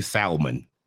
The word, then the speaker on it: salmon: We say salmon. – That's how I say it. And everybody's like, that's not how you say it. I'm like, well, then what is the L doing for? – Yeah. – What up, Rachel? How you doing, baby? Yeah. It's silent. Nice.